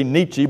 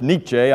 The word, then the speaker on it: Nietzsche: I used to say Nietzsche, but then I learned it's Nietzsche. I was mispronouncing his name.